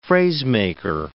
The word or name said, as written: phrasemaker